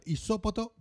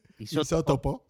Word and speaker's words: isótopo: El rover Curiosity ha encontrado una, un peculiar isópoto. Isópoto. – Isótopo.